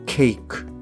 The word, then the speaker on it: cake: で、ここであの、 live のですね、単語の語尾っていうのは E になるんですけれども、語尾の E というのはほとんど発音されないんですね。まあ、例えば take とか make、 cake。まあこれらなんかも同様で、後ろに E がくっついてるんですけれども、 E っていうのは発音しません。